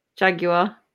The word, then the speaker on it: Jaguar: It's Jaguar. It's how it's said. It's Jaguar. Don't even try and go for this American English bullshit, because you stole our language in the first place, Cody.